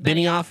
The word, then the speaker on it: Benioff: I think that we're in good hands with if I'm pronouncing that right, Beinhoff. – I think it's Benioff.